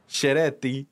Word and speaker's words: Ceretti: I saw they pronounced that with a ch. Ceretti.